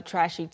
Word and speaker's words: trash: 발음을 주의해 주세요. 쓰레기 자체는 trash. – trash. – 그냥 모음이 trash. 마지막에 모음이 없어요. Trash. – trash. – 한 음절입니다.